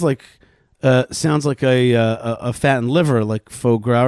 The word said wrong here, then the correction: foie gras: Foie gras. – Foie gras.